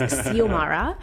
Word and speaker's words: Xiomara: I looked up how to pronounce her name because I definitely screwed it up in the first iteration of this conversation. So it's Xiomara.